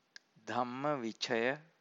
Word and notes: dhammavicaya: Pali

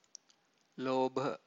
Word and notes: lobha: Pali

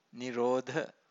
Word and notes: nirodha: Pali

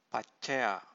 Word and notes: paccaya: Pali

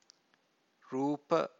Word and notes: rupa: Pali

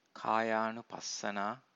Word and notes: kayanupassana: Pali

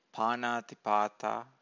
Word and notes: panatipata: Pali

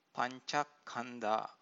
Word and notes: pancakkhandha: Pali

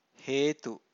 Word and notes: hetu: Pali

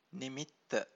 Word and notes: nimitta: Pali

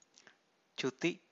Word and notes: cuti: Pali

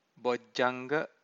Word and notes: bojjanga: Pali

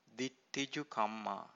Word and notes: ditthijukamma: Pali

